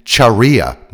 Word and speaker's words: Charia: Because of all this, this movement is sometimes called the Christian Charia movement, pronounced charia to differentiate it from Muslim sharia, because that's basically the same thing that hardline Islamists spout, except that these guys are Christians, mainly Old Testament Christian.